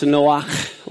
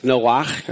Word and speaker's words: Noach: Now, in Hebrew, it's really pronounced Noach, like Bach, Beethoven and Bach. It's Noach.